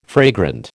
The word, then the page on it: fragrant: American English